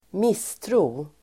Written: misstro

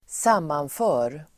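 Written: sammanför

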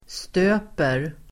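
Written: stöper

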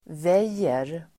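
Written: väjer

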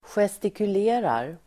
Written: gestikulerar

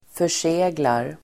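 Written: förseglar

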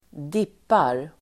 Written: dippar

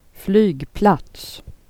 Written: platser

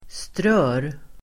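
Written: strör

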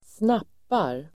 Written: snappar

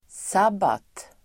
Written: sabbat

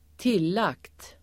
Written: tillagt

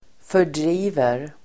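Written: fördriver

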